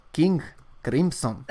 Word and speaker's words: King Crimson: Tú has propuesto que para empezar a romper con las situaciones convencionales, vamos a irnos con algo de Kim Kim Kim Crimson. King Crimson.